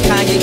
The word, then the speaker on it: Kanye: Now all I need is y'all to pronounce my name. It's Kanye, but some of my flags still say Kane.